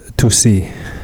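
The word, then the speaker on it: Tusi: Oh, uh, um, Tussi. – Yes, rhymes with pussy.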